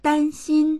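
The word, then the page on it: dan1xin1: Mandarin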